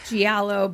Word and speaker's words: giallo: Like, some giallo, but also, like, is that how you say it? – Gallo. – Gallo? I think that's a word that I've never heard said, and I just read it. – It's gallo. Giallo.